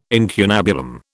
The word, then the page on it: incunabulum: British English